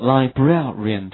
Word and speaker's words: librarian: 好， librarian 这个单词稍微它的读音和拼写有一点并不是那么的规律，叫做 librarian，其中 A 的发音是 air， R-A 的发音是 ear，所以连起来是 librarian，它表示的是图书馆的管理员。